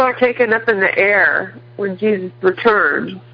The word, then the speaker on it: Aethyr: This is that's chapter 23, supplement B in the Book of Revelation series, and it's spelled A-E-T-H-Y-R, air.